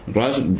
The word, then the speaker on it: Russ: Ross, recordemos que se dice así en inglés, pero se escribe R-U-S-S.